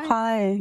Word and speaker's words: Suhai: Suhai.